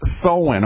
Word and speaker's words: Samhain: But in saying that, Halloween's origins date back to ancient Celtic festival of Samhain. It's pronounced Samhain, okay? Festival of Samhain.